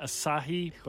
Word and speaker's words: Asahi: Ashaya. – I'd pronounce that Asahi, but okay.